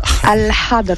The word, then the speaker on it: Al-Hadr: Il s'appelle Al Adr. Al Adr. Non. Comment tu le prononces? – Allah. Al-Hadr. – Ah voilà, effectivement.